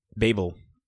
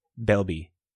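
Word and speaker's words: Belbe: Babel. No, not Babel. Bybel. How do you pronounce that? – What? – Belby. Belby? – Belby. – Oh, yeah. Yep.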